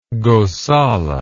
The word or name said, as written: gosala